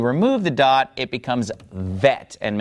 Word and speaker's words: Vet: But if we remove the dot from the middle of Bet, now it becomes pronounced vet, like a V sound.